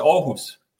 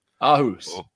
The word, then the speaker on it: Aarhus: But if you want to rehearse it, it's Aarhus. – Aarhus.